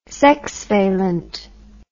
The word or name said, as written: sexvalent